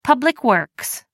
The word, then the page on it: public works: American English